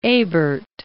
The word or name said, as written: Ebert